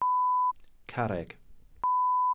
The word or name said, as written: carreg